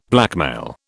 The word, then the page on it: blackmail: British English